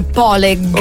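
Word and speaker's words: pålegg: Allora, si scrive paleg con paleg, 2 G finali, a quanto pare si legge poleg.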